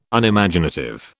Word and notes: Unimaginative: British English

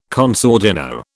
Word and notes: con sordino: British English

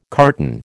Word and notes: carton: American English

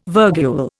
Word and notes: virgule: Indian

